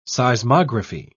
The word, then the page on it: seismography: British English